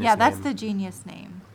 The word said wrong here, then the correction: genus: That's the genus name.